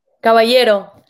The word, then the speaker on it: Caballero: Caballero.